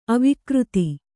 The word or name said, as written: ಅವಿಕೃತಿ